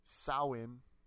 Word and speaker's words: Samhain: So it dates back to the Celtic festival of, Samhain, that's how it's pronounced.